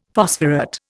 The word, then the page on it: phosphorate: Indian